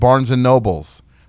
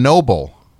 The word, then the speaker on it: Barnes & Noble: One thing, though, they are not called Barnes & Nobles. That is a mispronunciation now because I'm so flustered because of this. That is a mispronunciation. There has literally never been an S on Noble. That's the last name of the founder is Noble, not Nobles.